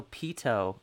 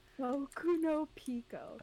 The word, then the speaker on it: Boku no Pico: Oh, I thought you said Boku no Pito. I was like, I haven't heard of that one. – Boku no Pico.